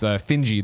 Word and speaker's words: Finji: As they pointed out, it's Finji, the developer. I'm probably saying it wrong. Maybe it's Finn. No, I think you got it right.